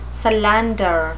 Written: solander

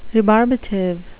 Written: rebarbative

